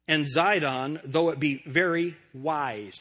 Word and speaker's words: Sidon: Tyre and Sidon, S-I-D-O-N, that's the way that we would pronounce it now. T-Y-R-E, Tyre and Sidon, those are the two cities that are being spoken of here.